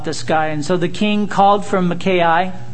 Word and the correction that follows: Micaiah: Actually, it's Micaiah. I practiced that and then lost it. But it's my long I ka.